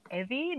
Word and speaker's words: Evvie: And I have been told that it is Evie, not Evie. – Oh. I would have said, is it Evie? – Yeah, no, it's Evie. It's pronounced, because in the book they say Evie like Chevy, not yeah, something else or whatever.